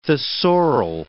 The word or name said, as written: thesaural